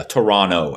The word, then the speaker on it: Toronto: Oh, Torontonians really pride themselves on not pronouncing the second T. – It's Toronto. What? – Yeah, Toronto, as if it had two N's.